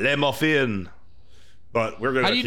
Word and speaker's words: Le Muffin: And it's like, the Le is French, but Muffin is English or American. – Oh, wow. Okay. – But we're going to say Le Muffin.